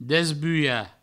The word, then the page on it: desbulhar: Provençal; desbuia